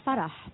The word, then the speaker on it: Farah: I call her Farah because that's how you say it in Arabic.